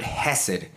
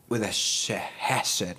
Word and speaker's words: Chesed: And you know, if you listen to the last episode, I said at the end of the episode, the word Hesed, that actually it's spelled Chesed, I guess, like chess or something, but pronounced with a Hesed, something like that.